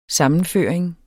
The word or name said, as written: sammenføring